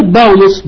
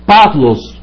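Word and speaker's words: باولوس (Παῦλος): بونص النطق الحديث بيتنطق بافلوس بافلوس بافلوس مش باولوس.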